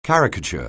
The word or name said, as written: caricature